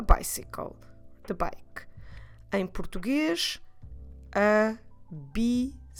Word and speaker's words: bicicleta: Feminine é uma palavra feminina, it's a feminine word, é uma palavra feminina, a bicicleta. That's right, four syllables. I will repeat slowly, devagar. Vamos repetir devagar. A bicicleta. A bicicleta.